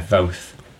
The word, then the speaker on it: Voth: Yeah, Voth. Pronounced like both.